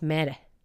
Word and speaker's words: Mater: Because uh, I was saying, oh, uh, you're, you know, the matter hospital. And they were like, it's meta.